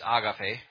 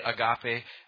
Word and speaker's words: agape: The word here is from the root word, and I'm going to pronounce it like I learned it in Spanish. It's agape, okay, which is the Greek word. Some people say agape, and there's all kinds of trying to look for the variations of the word, but it's a godly love, okay?